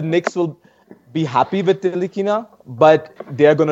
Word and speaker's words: Ntilikina: What are your thoughts on Frank Ntilikina? How do you say it? – Ntilikina.